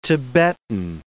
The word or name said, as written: Tibetan